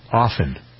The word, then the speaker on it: often: Often. – Okay. – T is silent.